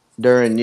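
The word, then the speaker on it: Duren: I don't know how to pronounce his last name, but I think it's Duran. – Duran, yeah.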